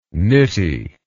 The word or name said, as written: nitty